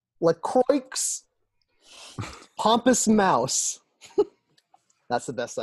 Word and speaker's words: LaCroix: Okay, so in an article, LaCroix confirmed it's pronounced LaCroix, and it mentions also that it rhymes with enjoy.